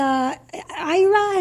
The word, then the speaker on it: AYRA: At the IRA, is that how I pronounce that? A-Y-R-A, IRA? – Yeah.